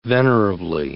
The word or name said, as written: venerably